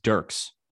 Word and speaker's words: Dierkes: Tim Dierkes of, I think, am I saying that right? Dierkes. How would you pronounce that? – Dierkes. Dierks. – Dierks? Really? – I think so. – Tim Dierkes. Yeah, that does sound way better.